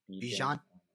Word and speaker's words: Bijan: Uh, Bijan.